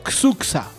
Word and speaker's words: Xuxa: Roberto, 90, 90 Chucha. Bueno, en realidad se pronuncia Xuxa. Así, si queremos en el español bien hablado.